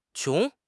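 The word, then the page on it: qiong2: tone 2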